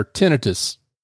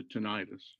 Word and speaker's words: tinnitus: Did yes. I get that pronounce, pronunciation right? – You pronounced it like a physician would pronounce it. That's because I've been hanging around a physician. – Um, as opposed to tinnitus. – Yeah, yes, which is what I used to pronounce it as.